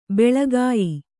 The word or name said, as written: ಬೆಳಿಗ್ಯೆ